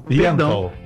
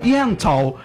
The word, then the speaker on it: Yentl: Ó, a querida Alessandra Maestrini, ela tá em peça, ela tem peça, que é Ente. Ente in Concert. – Yentl. – Eu vou fazer com pronúncia Yentl in Concert.